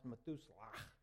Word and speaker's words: Methuselah: That's pronounced Methuselah.